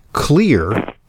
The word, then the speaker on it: Xlear: He's CEO and founder of Clear, Inc. That's spelled X-L-E-A-R. If you're a regular listener to Intelligent Medicine, you've, of course, heard about Clear. I've extolled its benefits. Clear starts with an X because it harnesses the benefits of xylitol, which is kind of spelled like xylophone with an X.